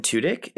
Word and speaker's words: Tudyk: and then Alan Tudyk, is that how he pronounces his last name? – Yep.